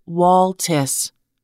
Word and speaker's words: Waltis: The game's name is pronounced Waltis. It is also spelled O-A-L-T-E-S.